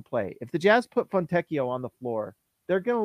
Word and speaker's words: Fontecchio: Is it Fontecchio or Fontecchio? – I am not an Italian speaker, but I know that Pinocchio is pronounced. – Pinocchio. Oh, that's true. Let's talk Fontecchio. like Pinocchio, Fontecchio.